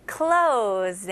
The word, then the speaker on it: clothes: 원어민들은 일단은 똑같이 clo로 시작하다가 z로 마무리합니다. 그렇게 한번 해볼게요. clothes. 너무 쉽죠? close, z, z 발음 나와야 돼요.